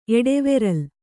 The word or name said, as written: ಎಡೆವೆರಲ್